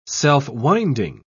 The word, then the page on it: self-winding: British English